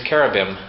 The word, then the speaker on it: cherubim: I believe the correct pronunciation is cherubim, and not that it will change your life any, but that, I believe, is how it is properly pronounced.